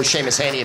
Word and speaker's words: Seamus Heaney: Or Seamus Heaney, if you wish to pronounce his name correctly.